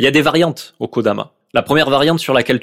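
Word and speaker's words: Kodama (木霊): T'as le kanji de l'écho qui se prononce Kodama.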